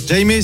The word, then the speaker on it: Jaime: Eh oui, 6h28 avec Jaime, salut. – Jaime, bonjour Jaime. – Oui, bonjour. – Est-ce qu'on prononce bien votre prénom déjà, pour être sûr? – Alors non, c'est J'aime. J-A-I-M-E.